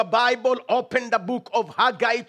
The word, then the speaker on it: Haggai: Haggai.